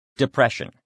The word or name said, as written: depression